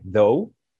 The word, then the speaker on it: though: O sea, en inglés hay como palabras que es este, though, que se escribe TH o UGH. Entonces hay un chingo de palabras que no, que no, este, y se pronuncia como D-O-U, DOU.